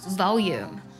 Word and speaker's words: volume: 这个单词是 V-O-L-U-M-E，读作 volume， volume，或者读作 volume 也是对的。